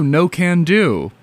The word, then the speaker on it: No Can Do: And next we're going to hear Knock and Do. Oh, No Can Do. That's how you pronounce it. You'll see what I mean if you look at like how it's spelled when I put it on the stream by Michael Hurley.